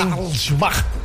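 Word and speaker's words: Harald Schumacher: Harold Schumacher. ¿Eh? Muy bien su pronunciación, como que también tiene raíces alemanas, ¿verdad? – ¿Cómo me oí?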